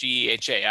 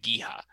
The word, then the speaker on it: GEHA: It is pronounced GEHA. I've always said like GEHA, but it's GEHA.